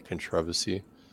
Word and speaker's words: controversy: You're, you're so so you're you're putting emphasis on the second syllable, right? Controversy.